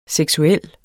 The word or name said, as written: seksuel